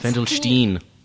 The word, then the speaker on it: Wendelstein: Wendelstein. – Which is... E-I. No, it's E-I, so you pronounce it I.